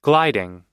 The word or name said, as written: gliding